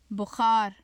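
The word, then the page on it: buxar: Urdu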